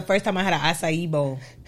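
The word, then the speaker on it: açaí: Round of applause of you for saying that correctly. Because some people can't say acai. – Oh, what are you saying? Acai? – Acai. It's my ignorant friend like to say acai eat.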